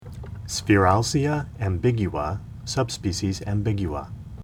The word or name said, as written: Sphaeralcea ambigua